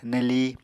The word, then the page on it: neli: Apatani